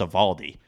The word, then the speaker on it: Eovaldi: Eovaldi – to your point with the name pronunciation, I was calling him E. Eovaldi all year on radio last year with Bradfo. And in September, he was like, it's Eovaldi. I'm like, what? He's like, it's pronounced Eovaldi.